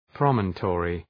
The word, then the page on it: promontory: ‘prɒmən,tɔ:rı